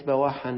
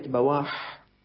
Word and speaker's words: بواح: فالكلمات التي يمكن أن تشكل نقلوها فضلا عن النطق، كتبوها حرفا حرفا تصريحا، يعني مو بس كتبوا كلمة بواحا، قال بالموحدة التحتية المفتوحة أو بفتح الباء الموحدة ثم واو ثم ألف ثم حاء، وهكذا سائر كلمات أحاديث رسول الله صلى الله عليه وسلم ليحفظوا لنا سنة النبي صلى الله عليه وسلم غضة طرية.